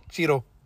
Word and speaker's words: Cheadle: Cheadle.